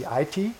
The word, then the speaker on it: IT: IT oder EDV, aber in der Schweiz darf man nicht EDV sagen, das war eines der ersten Dinge, die ich gelernt habe, sondern das ist die IT, Betonung auf dem I.